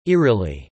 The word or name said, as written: eerily